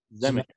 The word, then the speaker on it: Zemeckis: Zemeckis.